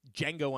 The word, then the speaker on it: Django: Django Unchained. – You got it. – And it's a silent D.